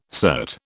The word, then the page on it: cert: British English